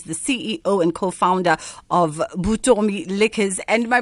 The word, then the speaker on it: Vuttomi: It's Vuttomi. – Vuttomi. – Like a bee. Vuttomi. – Oh, Vuttomi.